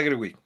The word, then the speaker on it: Gerwig: Greta Gerwig.